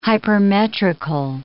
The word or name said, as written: hypermetrical